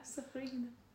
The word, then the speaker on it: Safryhina: Yeah, Safryhina.